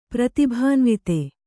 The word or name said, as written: ಪ್ರತಿಭಾನ್ವಿತ